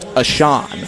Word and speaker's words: Ahcan: His last name is spelled A-H-C-A-N, pronounced Ashon, from Savage, Minnesota, USA.